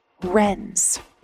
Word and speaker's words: Wrens: Shortened to its acronym, it spells WRNS, but it's pronounced like the bird, Wrens.